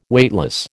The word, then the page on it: Weightless: American English